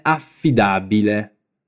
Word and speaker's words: affidabile: Fai attenzione alla doppia F in affidabile. Affidabile.